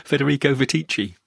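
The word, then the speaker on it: Federico Viticci: Federico Vitici.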